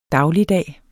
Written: dagligdag

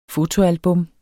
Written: FOTOALBUM

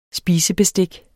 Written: spisebestik

